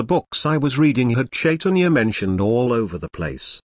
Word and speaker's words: Chaitanya: Do you know how long I thought the word Chaitanya was actually pronounced with a K sound in the front?